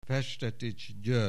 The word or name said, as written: Festetich, György